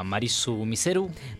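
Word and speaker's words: Malice Mizer: Que se pronuncia Marisu Miseru.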